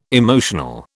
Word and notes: emotional: British English

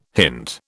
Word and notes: hint: British English

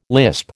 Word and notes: lisp: American English

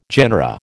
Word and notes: genera: American English

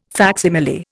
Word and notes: facsimile: Indian

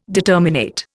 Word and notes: determinate: Indian

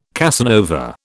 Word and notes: Casanova: British English

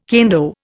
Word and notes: kendo: Indian

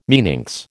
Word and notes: meninx: American English

